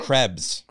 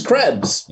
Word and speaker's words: Krebs: Krebs. – Krebs? – I'm saying it right, Krebs? – It is Krebs, yeah, but people say crabs, and it's offensive, don't worry.